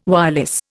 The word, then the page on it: wireless: Indian